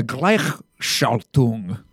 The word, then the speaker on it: Gleichschaltung: The Gleichschaltung. – Schaltung? – Yeah, the Gleichschaltung. – Gleichschaltung.